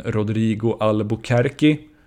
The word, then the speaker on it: Albuquerque: I det här avsnittet har jag intervjuat den brasilianske anfallaren Rodrigo Albuquerque som de senaste säsongerna har öst in poäng på college-nivå i USA.